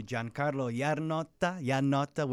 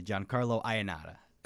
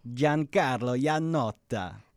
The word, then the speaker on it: Giancarlo Iannotta: It's either you could do Giancarlo Iannotta, which is the correct Italian way. But look, for Americans, you know, for the sake, let's just say Giancarlo Iannotta.